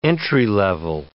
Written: entry-level

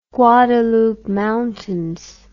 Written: Guadalupe Mountains

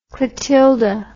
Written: Clotilda

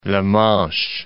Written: La Manche

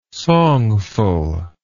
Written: songful

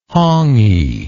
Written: hongi

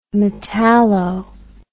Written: metallo

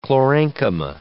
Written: chlorenchyma

